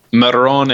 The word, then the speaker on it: Marone: Marone, it's pronounced Marone. that's Braun in Italian.